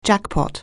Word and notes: jackpot: anglais

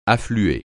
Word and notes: affluer: French

